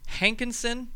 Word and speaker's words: Hankinson: And with us this afternoon is Pastor Adam Moline from Hankinson, North Dakota.